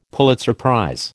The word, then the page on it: Pulitzer prize: American English